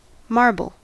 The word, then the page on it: marble: American English